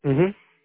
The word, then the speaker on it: Ukiah: UKIAH. You're in UKIA? Is that how you say it? – Mm-hmm.